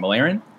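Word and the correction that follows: Mirialan: I believe it's called. It's Mary Ellen. – Yes, yeah. Mary Ellen.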